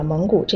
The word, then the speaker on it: 蒙古: 那中国的内蒙古省有很多草原，但是今天我们不说草原啦，还是来说这个“ 蒙” 字。内蒙古的“ 蒙” 是一个多音字。读三声时，用来指内蒙这个地方或者蒙古这个民族。